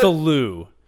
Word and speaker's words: Cthulhu: Cthulhu. Because like the C, it looks nothing. But the C would be silent. You know what I'm saying? Like Thulu could be Thulu.